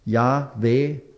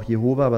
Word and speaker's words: Yahweh: Yahweh wird es wahrscheinlich ausgesprochen. Es ist ein Tetragramm, J-H-W-H im Hebräischen. Die Vokalisierung hat man ja unterlassen in hebräischer Schrift. Aber Yahweh, manche sagen auch Jehova, was wahrscheinlich falsch ist.